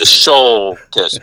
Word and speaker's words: Shultis: You got to get a little harder on the U, the Shultis, but that's all right.